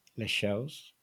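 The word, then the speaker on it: Leixões: Les Shows. It's quite hard. Les Shows. That's a really hard one because, like, the OE sound is tricky and then you have to try and swallow the S.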